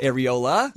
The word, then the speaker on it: areola: And she had that remember, it was like a silver star around her areoli. – Well, was it – is that how you pronounce it? – Areola? – Yeah. Yeah, there you go.